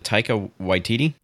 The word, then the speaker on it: Taika Waititi: So we get Nathan Fillion, and then I don't know how to say his name, but is it Taika Waititi? – Yes.